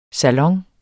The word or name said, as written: salon